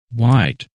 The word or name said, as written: White